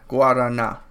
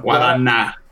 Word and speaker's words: Guarana: Guarana. Yeah. – Guarana. – Yeah, the na is the strongest part.